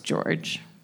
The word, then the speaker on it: Jorge: It's spelled Jorge, but it's pronounced George.